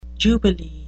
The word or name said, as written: jubilee